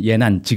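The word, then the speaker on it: 예난 (延安): 거기가 연안이거든요. 네. 예, 예난 지금 이제 중국 발음 예난인데 음.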